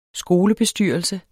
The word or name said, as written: skolebestyrelse